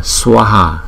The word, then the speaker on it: Swaha: Chữ A mà có dấu gạch ngang trên đầu chúng ta đọc kéo dài 2 nhịp so với chữ A bình thường chúng ta kéo dài 1 nhịp, cho nên chữ này chúng ta đọc là Swaha Swaha Swaha, không?